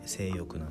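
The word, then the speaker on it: 正欲: あとは割と最近ネットフリックスで配信になった性欲、これはね正しいに欲と書いて性欲なんですけど、新垣結衣さん、あと稲垣吾郎さん、この辺りの方が出てる映画ですね。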